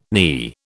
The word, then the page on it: knee: British English